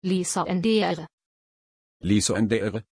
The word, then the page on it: Lysandre: German